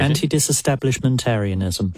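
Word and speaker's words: antidisestablishmentarianism: Anti-establishmentarianism. – Don't know. – I'm cooking under pressure. – Let's go with the official pronunciation. – Anti-disestablishmentarianism.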